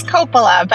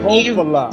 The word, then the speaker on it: Coppola: It's Coppola, but Coppola. That's what people usually say. – long O. All right, the Coppola gives me more of a "hey, forget about it" vibe, but it's all good. Coppola, long O.